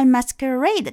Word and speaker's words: masquerade: 那么 masquerade 大家可以听一下它的发音，前面类似于 mask 就是面具，masquerade 就指的是这个化妆舞会。